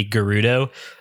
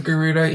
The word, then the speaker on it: Gerudo: Yeah, look, it's just fascinating to me because the usual mispronunciation of Gerudo is Gerudo. A lot of people take that as a, you know, a J kind of situation, a soft G, which I can understand because it's like there's no voice acting in in Ocarina of Time.